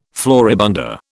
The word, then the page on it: floribunda: British English